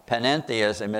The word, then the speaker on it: panentheism: Panentheism is its twin that says God is in everyone and everything. It's got an extra syllable.